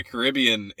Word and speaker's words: Caribbean: That is most definitely the Caribbean area, the Caribbean, I said it right the first time, area, is very well known for that stuff.